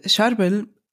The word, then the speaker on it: Charbel: Wie wird der richtig ausgesprochen? – Charbel. Ach so, ja. Ist der Heilige Charbel.